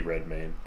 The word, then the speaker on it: Redmayne: Eddie Redmayne.